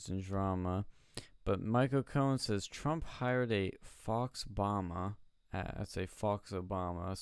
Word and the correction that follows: faux: A faux Obama. That's how that's pronounced.